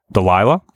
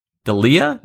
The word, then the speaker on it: Delia: Delilah? – Is it Delilah? It's not spelled like that. – Delia? – Delia? It's D-E-L-I-A.